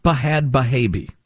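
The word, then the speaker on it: Bhabie: If you haven't heard this yet, the success of Bad Baby. – I believe it's pronounced Bahad Bahabi.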